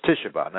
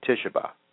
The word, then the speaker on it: Tisha B'Av: So it's Tisha B'Av, not Tu Bishvat, but Tisha B'Av. That's TISHAB'AV. Tisha B'Av. Again, the way to pronounce it is Tisha B'Av.